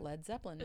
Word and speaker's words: Led Zeppelin: and then they ended up dropping the A in lead because they were afraid people would pronounce it as lead. – Yeah, lead, lead Zeppelin.